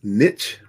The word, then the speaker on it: niche: Predominantly in the US, uh, we use niche, rhyming with pitch. Uh, but niche is actually French.